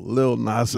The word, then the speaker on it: Lil Nas X: I got to say that right. Not little. Lil Nas X.